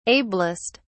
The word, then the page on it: ablest: British English